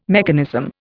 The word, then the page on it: mechanism: Indian